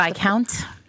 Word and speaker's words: Viscount: How do you say this word? The Viscount. Yes, the Merry Viscount.